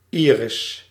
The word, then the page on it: iris: France; [i.ʁis]